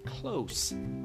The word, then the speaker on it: close: این صفت در زبان انگلیسی close با سه تلفظ میشه و هیچ ارتباطی نداره با close درسته که یک جور نوشته میشن ولی تلفظشون همونطور که دقت کردین در حرف S متفاوته